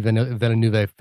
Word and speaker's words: Villeneuve: I thought, I've explained on the podcast before, I'm a Denis Villeneuve fanboy at this point.